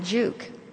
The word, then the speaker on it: Giauque: It was spelled G-I-A-U-Q-U-E, and we pronounced it Juke.